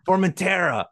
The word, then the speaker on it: Formentera: Formentera. – Formentera. I missed the R. – You need it in there.